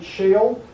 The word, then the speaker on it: Sheol: Now, another term that's used in the Old Testament is the word shoal. Some people pronounce it shale, S H E O L.